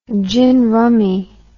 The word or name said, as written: gin rummy